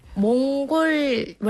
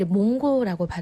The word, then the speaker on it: 몽골: 몽골 원래 몽골이잖아요. 표기 표기 네. 몽골이잖아요. – 네. – 뭐 L이 들어가요. 몽골이야. 그런데 예. – 이거를 이게 40 때 이후 한국 분들이 몽고라고 발음해요.